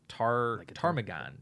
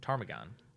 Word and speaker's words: ptarmigan: It's one of the PT words. How the fuck do you pronounce it? Ptarmigan. Ptarmigan.